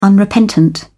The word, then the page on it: unrepentant: British English